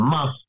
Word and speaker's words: mast: Now, the must is written M-A-S-T, but it's pronounced like must.